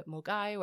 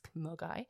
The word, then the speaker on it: Milngavie: And then I had another day on the Clycoastal path to get to Mulgai, where the start of the West Highland Way is. It's spelt Milngavie, by the way. It's Mulgai.